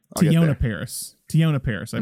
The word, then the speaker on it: Tiona Paris: Sorry. Tiona Paris. I mispronounced. Tiona Paris.